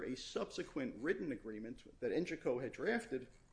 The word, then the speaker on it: Engico: And then at Engico's insistence, uh, and that's the pronunciation we used at trial was Engico, uh,